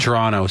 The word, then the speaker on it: Toronto: This video came down my TikTok feed recently of a girl from Toronto speaking with... I just said it wrong, didn't I? – Toronto speaking with the Toronto accent.